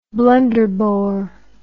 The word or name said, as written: Blunderbore